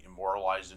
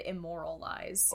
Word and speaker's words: immortalized: I mean, it says at the end, immortalized in his story. – Immortalized. – Mm-hmm. He said immoralized.